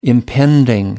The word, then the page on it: impending: American English